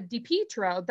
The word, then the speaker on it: Di Pietro: Well, so 6 degrees of separation, there is a Di Pietro, that's how they pronounce it here in Canton, their restaurateur family that owns a huge restaurant business here in Canton, Ohio, and a pizza business.